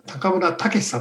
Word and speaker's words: 中村武: はい、今日のゲストは、水色クリップで最近富に有名になってきました中村武さんと読むんですか？ – そうですね、はい、はい、中村武さんに高松からオンラインで参加していただきます。